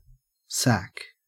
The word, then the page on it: sack: Australian English